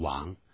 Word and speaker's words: 王: 要是因为前面指的是王室这些人，所以呢一定是两个以上，那么这个时候呢我们 neighbor 也跟着用复数了。 The ones are my neighbors。哪一天呢如果有机会在街上呢碰到外国人的时候，如果是碰到个美国人，假如他懂中文，他会把这个 W-A-N-G 王会念成王。